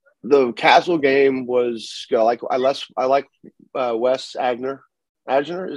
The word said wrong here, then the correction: Eigner: is that how do you pronounce it? – Eigner.